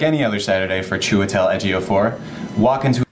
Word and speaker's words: Chiwetel Ejiofor: Ja, ich hätte noch die Info, wie Chiwetel Ejiofor ausgesprochen wird. Warte, mal sehen, ob ich es einspielen kann. – Chiwetel Ejiofor. Chiwetel Ejiofor.